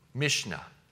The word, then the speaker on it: Mishnah: So beyond this whole idea of the Hebrew scripture, beyond the canon is what they call Mishnah. Mishnah. So that's your first blank there. I think it's up on the screen. It's spelled M-I-S-H-N-A, Mishnah.